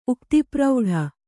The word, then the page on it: ಉಕ್ತಿಪ್ರೌಢೆ: uktipraudhe